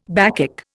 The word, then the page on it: Bacchic: Indian